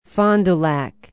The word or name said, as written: fond du lac